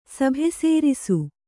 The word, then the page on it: ಸಭೆಸೇರಿಸು: Kannada